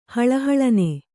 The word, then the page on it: ಹಳಹಳನೆ: kannada